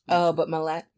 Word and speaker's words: Mallett: We have two young couples, and their names are Robert and Linda Scarberry, and Steve and Mary Mallett. It's spelled like Gillette, but Mallett. – Yeah. – Sounds okay. Pretty.